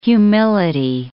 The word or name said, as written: humility